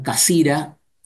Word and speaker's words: Cassirer: Por un lado, el filósofo Ernst Cassirer, los alemanes lo pronuncian Cassira, y el famoso Martin Heidegger.